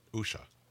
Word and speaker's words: Usha: Assuming he hasn't gotten remarried, he is married to, this is hard to pronounce, Usha. – That's it. Usha.